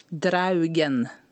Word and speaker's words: draugen: How do I say it? Not dragon, because the English word is dragon, but this word is? – Draugen.